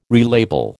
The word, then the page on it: relabel: American English